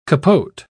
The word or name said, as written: Capote